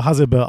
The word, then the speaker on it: Hasebe: Er selber spricht sich eher so Hasebe aus, aber das klingt relativ albern.